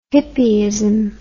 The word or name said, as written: hippyism